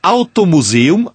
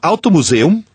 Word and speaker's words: Automuseum: E a dica do Carlos é Automuseum, Automuseum, assim como eu estou pronunciando, automuseum.volkswagen.de, de Alemanha, de Deutschland.